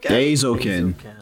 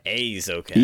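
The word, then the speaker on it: Eizouken: Aizouken. – Aizouken. – EI is pronounced A. – A.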